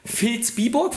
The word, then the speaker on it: Vilsbiburg: Steffen Kirchner, geboren im beschaulichen Vilsbiburg, wird das so ausgesprochen, Steffen? – Richtig, sehr gut, ja.